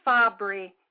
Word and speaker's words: Fabbri: My last name is Fabbri, and that is spelled F as in Frank, A-B-B as in boy, R-I.